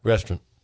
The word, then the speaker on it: restaurant: Restaurant. – Say it like that. – Paul Mano is one of the best restaurants I've been to. – That's it. It's two syllables, and the last syllable is runt.